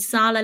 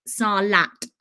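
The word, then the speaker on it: Sarlat: I keep saying Sala because they don't pronounce their T, but it's Salat.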